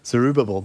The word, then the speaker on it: Zerubbabel: And so the date of the temple being rebuilt by Zerubbabel, how did you say that? Zerubbabel, there we go, in 516 BC.